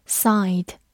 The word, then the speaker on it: side: side，s i d e，side，字母 i 在这里读它本身的音 i，末尾的 e 不发音。 side，它是一个名词或者形容词，表示方面、侧面或者侧面的。